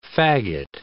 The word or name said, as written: fagot